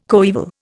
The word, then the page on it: coeval: Indian